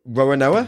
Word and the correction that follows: Roronoa: How do you say his first name? – Roronoa Zoro.